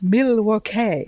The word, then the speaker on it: Milwaukee: So actually, it's pronounced Milwaukee.